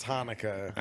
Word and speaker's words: Hanukkah: Chinooka. – Don't say it. Say Hanukkah. That's not how you pronounce it. It's Hanukkah.